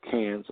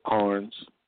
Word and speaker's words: Cairns: But when I went to Australia, I went to a place called Cairns or Carnes. Depends on who you ask if you pronounce it different ways.